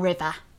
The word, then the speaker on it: Riva: I read the whole book pronouncing it in my head as River. – And then I heard her talk about the book at the Stylist Literary Festival and she pronounced it as river.